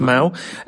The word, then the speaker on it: Mahou: So we will have Mau, apparently it's pronounced Mau, M-A-H-O-U.